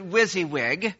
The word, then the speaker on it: WYSIWYG: Anybody know what, and it's, I pronounce it WYSIWYG.